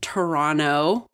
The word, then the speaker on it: Toronto: Shout out to Florence in Toronto. Don't pronounce the T. I did it right.